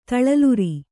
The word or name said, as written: ತಳಲುರಿ